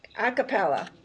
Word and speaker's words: a cappella: She has experience ranging from an a capo. Is that, am I saying that right? – A cappella. – Oh, it's separated, so it's like I'm going, that doesn't sound right. Groups to gospel choirs to acoustic bands.